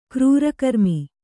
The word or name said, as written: ಕ್ರೂರಕರ್ಮ